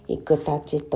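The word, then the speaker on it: 生大刀: 生きるたち、えー、大きな刀と書いていくたちというんですが、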